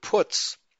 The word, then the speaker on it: Putz: In the German, the word Putz is pronounced like the English word P-U-T-S, as in he puts his shoes on 1 foot at a time.